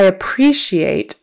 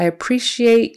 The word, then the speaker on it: appreciate: Okay. I appreciate, second syllable stress. I appreciate you bringing this issue to my attention.